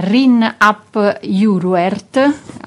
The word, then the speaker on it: Rhun ap Iorwerth: Poi il primo ministro gallese, che si chiama Rhun ap Iorwerth, almeno così mi pare di aver appreso la pronuncia. – Confermo.